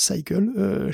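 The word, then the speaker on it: SYCL: Et il y a Cycle, alors c'est écrit Cycle, mais ça se prononce Cycle, chez Intel aussi.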